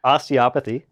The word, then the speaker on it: Osteopathy: Osteopathy.